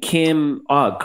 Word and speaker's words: Ogg: That was the district attorney's race where progressives got behind Kim Ogg, correct? Is that how you pronounce her last name? – That's how you pronounce her last name.